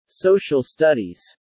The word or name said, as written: social studies